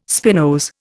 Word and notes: spinose: Indian

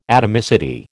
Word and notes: atomicity: American English

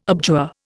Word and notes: abjure: Indian

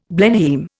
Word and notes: Blenheim: Indian

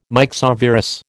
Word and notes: myxovirus: American English

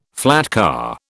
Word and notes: flatcar: British English

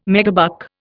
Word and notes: megabuck: Indian

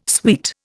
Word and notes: suite: Indian

